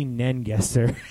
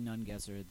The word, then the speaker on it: Nungesser: When he was when the president of the organization was announcing the dignitaries, he called him Billy Nengesser. Like, he mispronounced his name. – The stories, the chronicles of Billy Nungesser that I have been told.